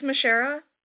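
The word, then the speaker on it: Michera: It's Mishara. – Can you spell that, please? – Yeah, it's M-I-C-H-E-R-A.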